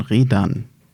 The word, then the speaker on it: Redan: Der Nachname ist ja fast noch das Einfachste, wobei da wohl, wenn ich Damenwahl glauben darf, und das tue ich natürlich, die Betonung eigentlich auf der zweiten Silbe von Redan ist.